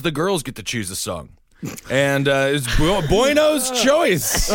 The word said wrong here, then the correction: Buono: Bueno's? How do you say his name? – Bono. – Bono. – Like Sonny Bono. – I know, but I see it as Bono. Bueno. – Yeah, it's spelled Bueno. – You just got racist eyeballs. – But and he's Italian. He's not Latin. – All right, he's Bono.